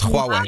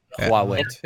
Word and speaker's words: Huawei: Ja, dat is waarschijnlijk het uitspreken. Ik heb het ooit uitgezocht. – Huawei. Ja? Huawei.